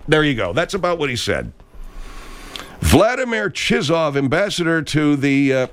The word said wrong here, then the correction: Chizhov: It looks to me like C-H-I-Z-H-O-V. Chizhov. That's what it is, bud, so we don't get your filth infecting this segment of the program.